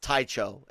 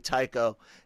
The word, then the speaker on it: Tycho: Tycho Selchu, as it was, someone had pronounced, someone had tried to say that it was pronounced Tycho, and I was like, no, it's gotta be Tycho, and he's a very important Rogue Squadron member.